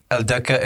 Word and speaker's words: Aldhakka: So how do you pronounce it? – El Deka, A-L-D-H-A-K-K-A.